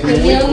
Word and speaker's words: Hermione: Hermione, es Hermione.